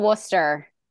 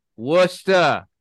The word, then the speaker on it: Worcester: So grew up in Worcester, Massachusetts. Is that how you say it? – That works for me. Yeah, I'm from good old Worcester. – Worcester.